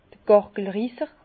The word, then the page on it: d'r Gàrgelrisser: Bas-Rhin; Herrlisheim